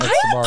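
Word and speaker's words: Sbarro's: How do you say it? Sbarros? – Sbarros. Oh, yeah.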